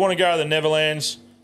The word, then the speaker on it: Netherlands: It's spelt Nether. Lands. But I prefer Nether. – Yeah, mate, you go for it. – Well, how would you say it? – I say Netherlands. Yeah. – Nether? – Yeah. – Yeah, but why is it spelled with a D? – No, I think that's... – It's silent. – It's silent. Now, it's silent.